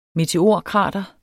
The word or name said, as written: meteorkrater